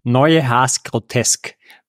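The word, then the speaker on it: Neue Haas Grotesk: It was originally called Neue Haas Grotesque. Help me out, man. How should it be pronounced? – Neue Haas Grotesque. – Whenever I say it, just imagine. But put that in your head. – The last word is a really hard one to pronounce for non-natives.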